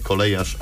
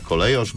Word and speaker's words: kolejosz: Stąd też kolejosz i to nie kolejarz, a kolejosz, bo to z kolei gwarowe. Określenie biorące się z Gwary Wielkopolskiej. Tak się wymawia nazwę tego zawodu.